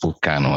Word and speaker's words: Vulcano: Vulcano in like I think in their native language is pronounced Vulcano as well.